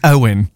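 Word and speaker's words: Eoghan: How did you just say it? – Echon Quig. – It's Owen. – Owen Quig. – Yeah, it's just an Irish spelling. – My apologies. – Owen Quig!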